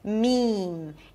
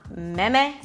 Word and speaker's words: meme: Meme, make sure you close your lips for the M, otherwise it's gonna sound like mean or something. Meme, it's not mem, it's not meme, it's meme.